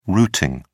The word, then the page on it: routeing: British English